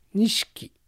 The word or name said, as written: Nishiki